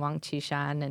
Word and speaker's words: Wang Qishan: Yeah, I correct his mispronunciation of a Communist Party leader named Wang Qishan, and he calls Wang Qishan.